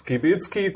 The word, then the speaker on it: Skibitzki: Morfire Kneipentalk, herzlich willkommen heute bei Käsekuchen Kölsch und bei mir ist der gute Professor Dr. Clemens Skibitzki, kurz Profski.